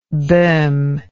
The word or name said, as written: bohm